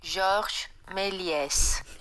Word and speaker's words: Méliès: I'm saying his name wrong. It's Millet, right? – Georges Méliès.